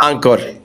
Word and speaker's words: Angkor Wat: Pero en definitiva, a ustedes también lo que les llamaba la atención es ir a conocer Angkor Wat. ¿Cómo se pronuncia? ¿Sabes tú cómo es, cómo es el tema? – Angkor Wat. nosotros lo pronunciamos siempre Angkor, Angkor Ancor.